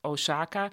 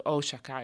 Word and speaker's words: Osaka: Nederlanders zeggen vaak Osaka. Maar die O is dus uitgesproken lang in het Japans. Dus Osaka. En ik kan dat niet meer veranderen in mijn hoofd.